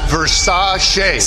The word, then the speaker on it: Versace: Pronounced Versace. Singer? That's Liberace.